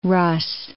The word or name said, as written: Russ